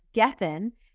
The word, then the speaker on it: Gethin: His last name is spelled G-E-T-H-I-N. So it's kind of like it's meant to be. If you want to get thin, you call Chris Gethin.